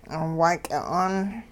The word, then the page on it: waaky'a'aan: hupa